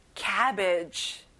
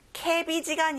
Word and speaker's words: cabbage: 양배추, cabbage. 케비지가 아니라 입을 크게 벌려서 cabbage.